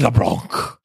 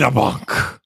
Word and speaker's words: LeBlanc: If you're going to say his name, you've got to say it correctly. It's Wade LeBlanc. – I'm sorry. Could I have that one more time? – It's Wade LeBlanc.